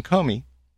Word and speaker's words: Comey: Former Deputy Attorney General Jim Comey testified.